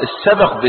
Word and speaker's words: السبق: السبق بسكون الباء، السبق، السبق بسكون الباء هو السباق. يبقى في فرق بين تحريك الباء وبين، يبقى السبق بتسكين الباء يعني المسابقة.